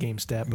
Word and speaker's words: GamStat: It's pronounced GameStat, but it's spelled GamStat.